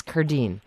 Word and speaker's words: Cardin: It's spelled Cardin. It looks like Cardin, but it's the my father always said it's the Italian pronunciation of a French name. Mm. So in France I would be Cardin, but in anywhere else in the U.S. I'd be Cardin, but it's Cardine.